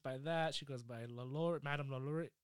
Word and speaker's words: Lalaurie: And we're going to talk about Madame Marie Delphine Lalaurie. I can never say the name right. – Lalaurie. – It's French. – It's French with an accent? – French with an accent.